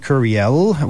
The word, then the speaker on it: courriel: The word courriel, and you have to say it like that, too, each time, recently appeared in the Language Commission's official publication, and use of the term will be mandatory throughout the French government.